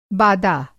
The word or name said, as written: बादा